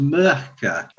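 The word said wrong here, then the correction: Myrkur: I don't know. – You'll find it's pronounced Mirkur. – Mirkur.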